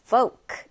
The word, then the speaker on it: folk: 자, 여러분, 발음 끼워 한번 잘 볼게요. L 발음이 나지 않아요. 그쵸? L이 들어가 있긴 하지만, folk. 이렇게 발음이 됩니다.